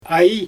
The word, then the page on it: aj: Xyzyl